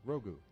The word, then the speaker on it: Grogu: All right, caller 2, you could win these will call tickets for Mandalorian and, how do you say it? Grogu? – Grogu.